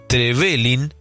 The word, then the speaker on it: Trevelin: Trevelin se pronuncia así, con el acento en la V de Trevelin, y por regla ortográfica del español no se le pone tilde.